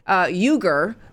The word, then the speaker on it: Uygur: Well, there was one guy who was with them, with that group, and his name is spelled, his first name is spelled C-E-N-K, but it's pronounced Jank. Jank Uger, which is spelled U-Y-G-U-R.